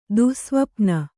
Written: ದುಸ್ಸ್ವಪ್ನ

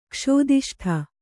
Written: ಕ್ಷೋದಿಷ್ಠ

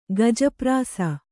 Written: ಗಜಪ್ರಾಸ

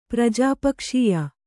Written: ಪ್ರಜಾಪಕ್ಷೀಯ

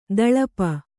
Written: ತಳಪ

